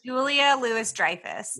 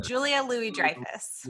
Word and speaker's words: Julia Louis-Dreyfus: And I'm going to butcher her name, is it Louise Ann Dreyfus? – Julia Louis-Dreyfus. Yeah. Julia Louis-Dreyfus.